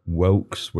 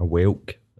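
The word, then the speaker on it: whelk: You're the guy that sold candy apples, Wilks, which are like, do you know what a Wilk is? A Welk, it's spelled.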